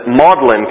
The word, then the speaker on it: Magdalen: And as a result, he took a demotion and became a common reader at Buckingham College, which later became known as, they pronounce it, Magdalen College, but we spell it Magdalen College after Mary Magdalene.